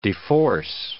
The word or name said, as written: deforce